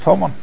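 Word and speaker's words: Homon (המן): So therefore that word can be pronounced Homon.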